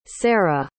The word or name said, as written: cera